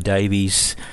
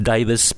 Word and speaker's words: Davies: Ray and Dave Davies, uh, sometimes pronounced Davis, but I'll call him Davies.